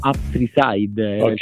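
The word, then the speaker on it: Up3side: Esatto, perché non vorrei pronunciarlo sbagliato. – Lo capisco. Sì, ci chiamavamo Up Freeside. – Immaginavo, però, alcuni invece il tre lo fanno come la E, quindi ho detto non vorrei mai.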